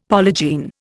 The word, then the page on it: polygene: Indian